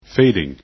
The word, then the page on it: fading: english